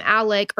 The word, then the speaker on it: Alex: His name it's spelled Alex, but everybody calls him Alec or Alec.